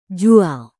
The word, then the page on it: jual: Indonesian